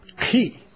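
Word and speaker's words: key (χ): The way you really pronounce it is key. But you can go like C-H, Christ.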